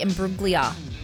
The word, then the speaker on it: Imbruglia: And Natalie Imbruglia for Torn. – Yep. – Did I say that right, Scotty? – No G.